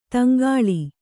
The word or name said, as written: ತೆಂಕಳ